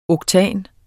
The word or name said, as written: oktan